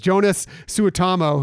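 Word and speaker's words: Joonas Suotamo: Going through some of the rest of the cast here, another name I'm going to totally screw up here is Jonas Suetamo, who played Chewbacca. I'm probably butchered that, but no, you nailed it.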